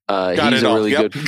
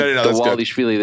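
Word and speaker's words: Dvalishvili: Dawalishvili. – Got it. – Yeah. Well, because I was saying Dawalishvili, there's no W in there, but that's how you pronounce his name.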